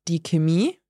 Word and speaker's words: Chemie: Or we have die Chemie. – Die Chemie, like chemistry. – Yeah. – Would I have heard that pronounced die Chemie? – Yes, probably. Yeah. So here, this one really varies on where you are.